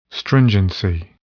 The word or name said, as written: stringency